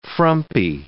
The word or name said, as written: frumpy